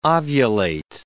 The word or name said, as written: ovulate